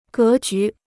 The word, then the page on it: 格局: gé jú